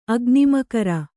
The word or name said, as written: ಅಗ್ನಿಮಕರ